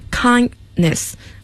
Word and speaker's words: kindness: 因为在英语的一个表达过程中，如果两个辅音连在一起，第一个辅音要说的轻一些，所以是 kindness，可以不发音。